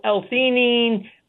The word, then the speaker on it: L-theanine: Okay, so the same thing would kind of go for, like, I can never say this correctly, but the L-theanine? – Yeah, so L-theanine.